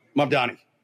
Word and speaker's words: Mamdani: Resurfaced video shows Zohran Mamdani. Am I saying it right? – Mamdani.